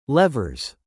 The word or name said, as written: levers